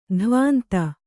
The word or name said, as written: ದಾವಂತ